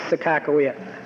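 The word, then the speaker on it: Sakakawea: Well, the Native American people in our part of the country would argue, Your Honor, that it's not a corruption, but the actual pronunciation of the name is Sakakawe.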